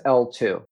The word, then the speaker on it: eltoo: And that's L2. And L2, the scientific name of L2, which, by the way, is spelled E-L-T-O-O.